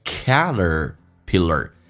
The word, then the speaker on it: Caterpillar: 来看一下，打开口腔，哎， Caterpillar， T 可以发 D 这个发音，在美式发音轻轻的卷下舌头， Caterpillar，哎，这个发音夸张一点， Caterpillar shows，后面加 S 发 Z 这个发音， shows the perfect T 跟 example 可以有一个连音，叫做 perfect the example， perfect the example， example 跟 of 有一个连音，叫 example love。